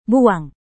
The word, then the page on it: buang: Indonesian